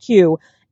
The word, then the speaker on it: Kyu: It's KYU, pronounced Q.